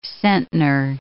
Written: centner

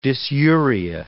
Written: dysuria

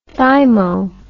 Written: thymo

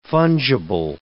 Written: fungible

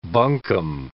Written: buncombe

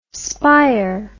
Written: spir

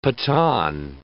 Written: Pathan

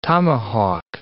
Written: tomahawk

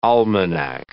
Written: almanac

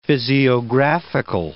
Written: physiographical

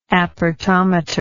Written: apertometer

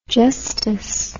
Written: justus

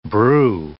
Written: brew

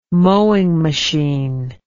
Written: mowing machine

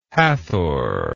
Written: Hathor

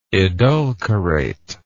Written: edulcorative

